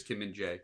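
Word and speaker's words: Kim Min-jae: Typically how I hear it, but okay. It might actually be pronounced Jai, but I typically hear it as Kim Min Jae.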